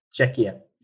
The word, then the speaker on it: Czechia: I don't know about how you pronounce it. Yeah. Czechia.